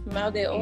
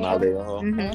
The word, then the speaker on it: mal de ojo: Mal de ojo. – Mal de ojo.